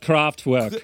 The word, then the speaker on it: Kraftwerk: Except this song was called Expo 2000 by the German industrial band Kraftwerk. Which, you know, it's pronounced Kraftwerk. – Yes, Kraftwerk.